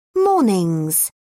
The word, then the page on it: mornings: British English